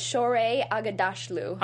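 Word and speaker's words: Shohreh Aghdashloo: Yeah, and then we have Shorin Agashilu. – I think it's Shore Agadashlu. – Agadashlu, okay.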